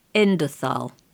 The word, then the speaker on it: Endothal: Was it Endosol? – Endothal, yes, with an E. – How do you spell it? E-N-D? – E-N-D-O-T-H-A-L. Endothal.